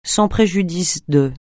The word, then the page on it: sans préjudice de: francais